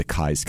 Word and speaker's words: Keyes: For those who do not know, I'm with the Keyes Company. Some people think it's called Keyes Company. It's spelled K-E-Y-E-S.